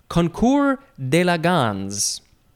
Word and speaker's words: concours d'élégance: It is concours d'élégance. Concours d'élégance. It is spelled, it's two words. First word, C-O-N-C-O-U-R-S. Mm-hmm. You don't pronounce the S. Mm-hmm. And then the second word is D apostrophe E-L-E-G-A-N-C-E.